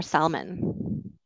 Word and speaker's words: salmon: So it's salmon is the word for this one. Very good. It's a silent L. So you do not say the L.